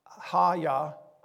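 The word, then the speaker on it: hayah: And he said, thus you shall say to the sons of Israel, I am, and the Hebrew word is Haya, I'm sure I say that wrong, it's H-A-Y-A-H, has sent me to you.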